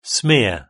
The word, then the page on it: smear: British English